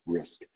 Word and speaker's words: Wrisk: That is spelled W-R-I-S-K, Risk.